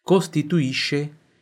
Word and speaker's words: costituisce: La Calabria è la regione che costituisce la punta dello stivale.